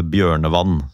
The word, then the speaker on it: Bjørnevatn: Vant 3-0 over Bjørnevann, som de visst skal uttales. Bjørnevatn, som det skrives.